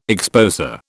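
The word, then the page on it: exposé: British English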